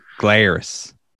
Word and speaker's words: Glarus: How would you pronounce the G part of this new Glarus? – Glarus. – It's Glarus. From the town of New Glarus, Wisconsin.